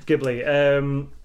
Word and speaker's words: Ghibli: Because I was like, is it Ghibli or Ghibli? And I read the. Katakana for it, and that was like, oh right, that is just genuinely how you pronounce it then. Mm-hmm. Ghibli.